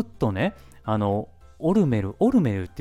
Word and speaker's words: オルメル: で、ショーを見た時に、オルメルって言うんですよ。えーとか思って。マジでえ、それ、正式の発音なんえ、オルメルっていうのみたいな感じでめっちゃこう、なんか、そわそわしてたんですよ。